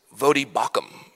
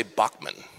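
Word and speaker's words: Voddie Baucham: Just like I had to practice Vodi Bakum. Most people say Bachman. It's not.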